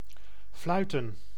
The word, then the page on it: fluiten: [flʌj.tǝː]